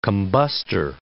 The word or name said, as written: combustor